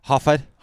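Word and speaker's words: Horford: Yeah, Hofford.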